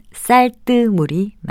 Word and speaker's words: 쌀뜨물: 흔히 쌀을 씻고 난 뿌연 물을 쌀뜨물로 발음하는 일이 많죠. 하지만 이것은 쌀뜨물로 쓰고요. 발음도 니은 받침이 없는 쌀뜨물이 맞습니다.